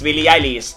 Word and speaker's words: Billie Eilish: Ahora sí lo he dicho bien. Es Billie Eilish.